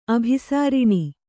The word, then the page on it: ಅಭಿಸರಣ: abhisaraṇa